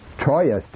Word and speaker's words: Troyes: Chrétien of Troyes, C-H-R-E-T-I-E-N, with a circumflex over the E, C-H-R-E-T-I-E-N, de. And in Old French, they would have said Troyes, T-R-O-Y-E-S. In Old French, all the consonants are pronounced.